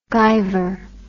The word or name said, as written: gyver